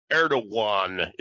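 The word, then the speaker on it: Erdoğan: First of all, the way the Turks pronounce that G in there, it's almost has a W sound. Erdogan is the way he pronounced. Correct pronunciation on it, because you'll hear call him Erdogan because it's the anglicization of it.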